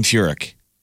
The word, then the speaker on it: Furyk: No, Jim Furyk. It's Furyk is the name.